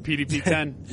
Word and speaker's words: PDP-10: Yeah, that's the PDP-10.